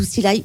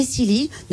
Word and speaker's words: Cly: Alors, Silly, qui ne se dit pas du tout Silly, mais Silly, mais ça s'écrit C-L-Y.